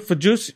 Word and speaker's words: Fauci: Fauci. – If you listen to what he said Sunday, Fausti.